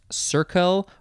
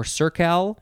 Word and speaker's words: cercal: I believe it would be pronounced circle or circal.